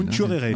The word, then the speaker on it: Nchourere: Ou Nchourere, dit-on, paraît-il. – Nchourere. – Oui, c'est ça, c'est comme ça que ça se prononce.